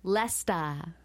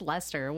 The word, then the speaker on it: Leicester: And I was like, how do you say this name? Lesta in British. – Lesta. – And I'm like, it's just Lester.